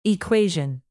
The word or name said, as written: equation